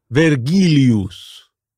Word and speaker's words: Vergilius: Virgilius. – Not bad. Yeah. Not bad. You know what? It's because you guessed it was Virgil, and the Vergilius sounded pretty good. We pronounce the V as a W.